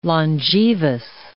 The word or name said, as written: longevous